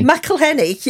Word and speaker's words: McElhenney: McElhenney.